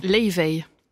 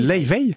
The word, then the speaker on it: Laufey: Leivey. – Comment ? – Elle est islandaise, Leivey. – Ça se dit comme ça ? – Ça se dit Leivey. Ça s'écrit L-A-U-F-E-Y, Leivey. – Ça fait des mois que je dis faux.